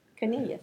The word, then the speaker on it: Cornelius: You almost said it right. Cornelius. – Cornelius. – Yeah, that's right.